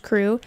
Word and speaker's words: CHRU: The Carolina Harm Reduction Union, known as CHRU, pronounced CRU, is a student-led program that provides life-saving harm reduction resources to the public of Chapel Hill.